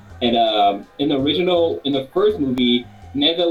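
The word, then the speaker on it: Nezha: So I think it's pronounced Neza. It's N-E-Z-H-A, I believe. – That's it. So Neza, it's New God, Neza Reborn, and seriously, it's not Japanese animation, it's Chinese animation.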